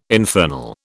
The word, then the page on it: infernal: British English